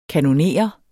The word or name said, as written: kanoner